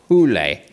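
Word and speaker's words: Hole: His surname translates as Hill in English, but in Norwegian is pronounced Hule.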